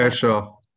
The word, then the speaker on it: Ayrshire: I think it's Ayrshire. How do you pronounce it? – Ayrshire.